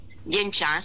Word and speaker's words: Dienchan: Dienchan si scrive Dien, così come si pronuncia, D-I-E-N di Napoli, e Chan si scrive con C-H-A-N, riflessologiadienchan.it.